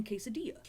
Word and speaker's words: quesadilla: It's the same double L. It's the same Spanish tortillas. – Rule. Tortilla and quesadilla.